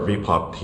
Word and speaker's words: VPOP: So first of all, I want to credit our VPOP team. So VPOP stands for Virtual Populations for Obesity Prevention. I'll explain what that means in a short bit. It rhymes with K-pop.